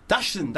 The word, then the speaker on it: Dachshund: Is it Dachshund? – Dachshund, that's it.